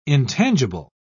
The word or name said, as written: intangible